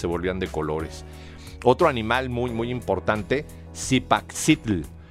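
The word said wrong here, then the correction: Cipactli: perdón, Zipaxitl Zipactli se pronuncia, perdón.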